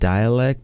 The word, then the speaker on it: Dalek: So Dalek, I think it's pronounced Dialek, but it's D-A-L-A-K. D-A-L-E-K.